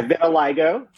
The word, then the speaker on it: vitiligo: Yeah, vitiligo.